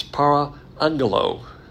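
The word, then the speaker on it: para angelo (παραγγέλλω): It's para angelo.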